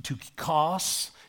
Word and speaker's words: Tychicus: In a Greek, it's pronounced Tychicus.